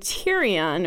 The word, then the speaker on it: Tyrion: From watching the TV show, I've always liked Olenna and Brienne for girls, Theon and Tyrion for boys. I guess you can see my clear naming preferences. I can't believe the name Tyrion, or yeah, it's pronounced Tyrion, hasn't taken off.